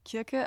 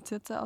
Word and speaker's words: Kirke: Kirke, also wird manchmal auch Zirze ausgesprochen, kommt drauf an.